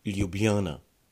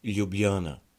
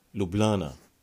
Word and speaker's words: Ljubljana: If you want to pronounce it very correctly, you would want to say Ljubljana. Ljubljana. We the locals, however, we like to ignore J's in the word, which may sound a bit harder, but in the same time, more natural and easier to pronounce.